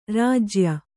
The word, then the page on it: ರಾಜ್ಯ: rājya